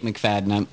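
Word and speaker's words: McFadyen: My name is also Jake McFadden. It's spelled F-A-D-Y-E-N. If you want to creep me on Facebook, go ahead. – F-A-D-Y-E-N. – F-A-D, Fad-Yen. Like fat yen, like lots of money in Japan.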